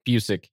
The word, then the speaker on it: Busiek: I'm going to butcher his name, but it's Kurt Busiek. Busiek, yeah.